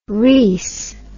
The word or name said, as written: rhys